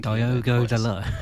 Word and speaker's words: Diogo Dalot: To be fair, I'll also give Diogo Dalot a special mention, because I think Diogo Dalot. That's not how you say it, surely. – Yes, it is. – That's weird. – Well, that's his name.